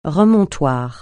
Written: remontoir

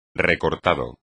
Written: recortado

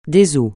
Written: ail

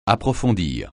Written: approfondir

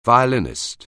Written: violinist

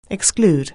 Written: exclude

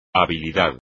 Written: habilidad

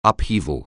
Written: upheaval

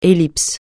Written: ellipse

